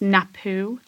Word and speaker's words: Napue: Um, and it's called well, I think it's pronounced Napue gin, okay. – N A P U E, and it's distilled in Kyrö Distillery, um, which is further north in Finland.